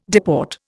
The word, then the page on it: deport: Indian